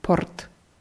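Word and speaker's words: Port (порт): Ein anderes Wort, das zu dieser Gruppe gehört, ist das Wort Port. – Port. – Port. Also dieses R muss man schon hören. Das ist das russische R. Port.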